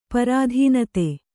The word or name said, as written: ಪ್ರಧಾನಿತಿ